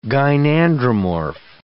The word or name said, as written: gynandromorph